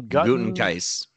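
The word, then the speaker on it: Gutekunst: Gutenkeist. – Gutenkeist. That's a very Green Bay Packers GM name, I will say. – Absolutely, yeah. German Midwestern guy. – Yes, a little hefty. – Yeah. – Gutenkeist.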